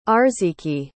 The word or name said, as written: Arziki